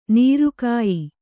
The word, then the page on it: ನೊರೆಕಾಯಿ: nore kāyi